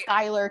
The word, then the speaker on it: Schuyler: So I went to high school in Schuyler County, which is which is spelled S-C-H-U-Y-L-E-R, like the dude from Hamilton.